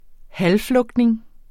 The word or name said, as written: halvflugtning